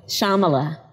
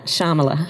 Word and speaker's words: Shyamala: Shyamala. So the Y is silent. Shyamala.